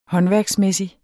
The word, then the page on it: håndværksmæssig: ˈhʌnvæɐ̯gsˌmεˀsi; -ˌmεsi